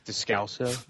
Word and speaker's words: Descalso: Descalso.